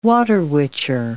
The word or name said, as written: water witcher